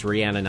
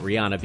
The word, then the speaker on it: Rihanna: Apparently, I just missed Rihanna, and I know it's pronounced Rihanna, not Rihanna, because I checked on it, who got more than just the one freebie nail that I had done.